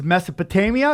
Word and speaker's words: Mesopotamia: The last point I want to bring up about last episode, and it really opened my eyes, was Mesopotamia. Is that, I pronounce it correctly? – Yeah.